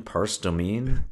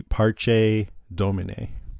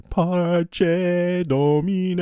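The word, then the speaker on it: Parce Domine: Can I just say, pronunciation, Pars Domain, Parse Domine? – I think it's actually Parche Domine. – Okay. – Because I listened to the chant and they said, Parche Domine.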